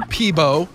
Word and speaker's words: Peabo: Just for clarification purposes, the Celine Dion song with Beauty and the Beast, Peebo Bryson was on that one too. – Oh, well, there you go. I still don't even know what you're saying. What is his name? – Peebo Bryson. – How do you spell that? – P-E-A-B-O.